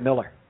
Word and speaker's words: Mueller: Oh, it's actually Bill Miller, but he's one of those who spells it Mueller and pronounces it Miller.